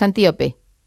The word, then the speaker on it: Antíope: ahondas mucho en la relación de Teseo con Antílope, pero me gustaría no sé, lo he dicho mal el nombre, es Antíope.